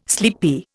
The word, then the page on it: sleepy: Indian